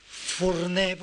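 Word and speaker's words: forner: La, R final no se pronuncia en, catalán. Mm, y entonces la palabra furné, por ejemplo, panadero, furné, furné, o hornero si quieren, furné, se escribe con R final, pero es que ya el diminutivo de Furné es Fourneret, nombre bajito, Fourneret.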